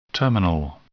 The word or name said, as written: terminal